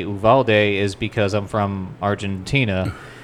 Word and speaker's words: Uvalde: That's when I when I read that, that's how it's pronounced. is it's Uvalde. Yeah.